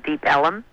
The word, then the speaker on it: Deep Ellum: Then off to the other side, the east side of downtown Dallas, there's an area called Deep Ellum, and that's spelled E-L-L-U-M, and actually it's a bastardization of the word Elm Street, E-L-M.